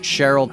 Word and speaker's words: Cehryl: This is Cehryl, the artist known as Cehryl, C E H R Y L. The the H and the E are flipped, but it's pronounced Cheryl.